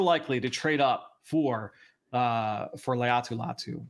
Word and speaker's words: Laiatu Latu: How is it pronounced? – Laiatu Latu.